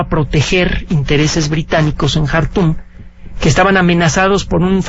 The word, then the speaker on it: Khartoum: Hay una película que se llama Khartoum, se escribe K-H, Khartoum, se pronuncia.